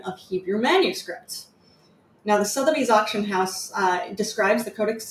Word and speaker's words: Sotheby's: I always say Sotheby's.